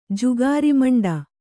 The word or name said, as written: ಜುಗಾರಿಮಂಡ